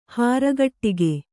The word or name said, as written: ಹಾರಗಟ್ಟಿಗೆ